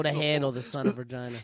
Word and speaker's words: Vergina: It is spelled V-E-R-G-I-N-A. – Virginia? – Virginia. The son of Virginia.